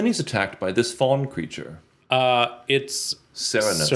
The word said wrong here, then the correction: Cernunnos: Cernunnos. Now, some people would say Kernanos, mm-hmm. but uh, I have the official pronunciation on Google as Cernunnos, the uh, antler horned god.